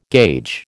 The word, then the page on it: gauge: American English